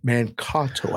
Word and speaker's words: Mankato: The guys that found the way to get that link to work, Mankato. I just got a text. That's how it's pronounced.